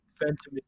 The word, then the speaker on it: Ventimiglia: Ventimiglia.